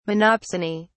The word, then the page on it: Monopsony: British English